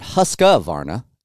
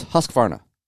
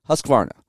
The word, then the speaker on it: Husqvarna: It's not Husqvarna, it's Husqvarna. It's tough to not put an A in between the Q and the V. I understand that. I know I don't spell it that way, but it's tough to pronounce it that way.